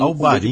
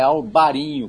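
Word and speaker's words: Albariño: Em Portugal é Alvarinho com V e NH, e na Espanha é Albarinho com B de bola e o N com tio em cima, né, como se escreve em espanhol.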